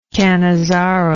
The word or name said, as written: cannizzaro